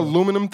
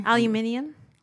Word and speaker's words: aluminium: In Spanish, it's more closer to the British pronunciation because I've always said aluminium.